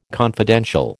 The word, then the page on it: confidential: American English